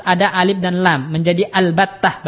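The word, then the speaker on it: al-battah (البتة): Jadi ada dua cara baca, ya. Pertama, boleh dengan alif lam dan itu madhabnya Sibawaih. Boleh dengan membuang alif lam dan itu madhabnya Kufah. Boleh al-battah, boleh battatah, gitu ya, tanpa al.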